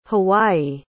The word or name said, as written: hawaii